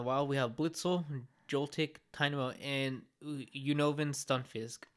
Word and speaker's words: Tynamo: Tynamo. How do you pronounce that? – Yeah, Tynamo.